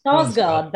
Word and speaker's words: Skarsgård: Skarsgård, that's it.